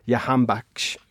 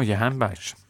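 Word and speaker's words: Jahanbakhsh: They've got one winger to watch out for, Arireza Yahanbakhsh, which I'm sure I've tortured his pronunciation there. – No, Yahanbakhsh. If he gets into a scuffle with another player, I wonder what the commentators will say.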